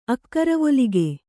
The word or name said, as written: ಅಕ್ಕರವೊಲಿಗೆ